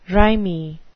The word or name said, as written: rimy